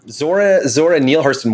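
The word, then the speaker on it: Zora Neale Hurston: Zora Neil Hurston, one word at the end, not to be confused.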